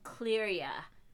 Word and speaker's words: Clearya: One is called Skin Deep, one is called Detox Me, and one is called Clearia, and it's spelled Clearya.